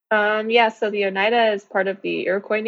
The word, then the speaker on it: Oneida: Was it a one, Oneida? – Oneida.